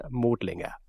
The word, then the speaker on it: Modlinger: It's not an umlaut, like the O with the two dots over it. It's in German, I wouldn't pronounce him as Florian Modlinger. It's Florian Modlinger.